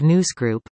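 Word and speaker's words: Nous Group: Pagnutti spoke to the hiring of News Group, pronounced as in, Mouse, as the firm that has been engaged to perform the review.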